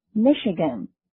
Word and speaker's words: Michigan: Michigan, M-I-C-H- I-G-A-N Michigan 再说一遍 Michigan M-I-C-H-I-G-A-N Michigan 根据它的发音，我想你一定能猜得到，这就是密西根州。那么以密西根州的名字来命名的大街该怎么说呢？ – Michigan Street。下一个生词是 lawyer， L-A-W-Y-E-R, lawyer. 再说一遍 lawyer. L-A-W-Y-E-R, lawyer.